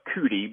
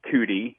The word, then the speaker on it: Judi: There's also Mount Judi, or over there they call it Kudi because they can't pronounce a J. They pronounce it with a C or a K. So Mount Kudi, some people think that it's about 50 miles north of Mosul Ararat, and it's in the southwest part of Ararat.